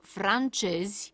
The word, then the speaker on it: francezi: În această situație suntem puși în fața alternanței fonetice dintre literele Z și J, iar formele corecte de plural ale acestor cuvinte nu sunt cele cu J, așa cum am pronunțat mai devreme, ci cele cu Z, adică francezi, englezi, danezi, finlandezi, japonezi, chinezi.